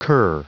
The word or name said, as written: cur